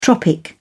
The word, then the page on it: tropic: British English